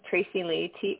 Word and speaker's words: Tracilea: My Instagram handle is my first name, Tracy Lea, TRACILEA.mindset. And Facebook is Tracy Lea Young. – Same spelling, Tracy Lea? Because I completely mispronounced it first. I thought it was like Tricilia.